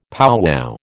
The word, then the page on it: powwow: American English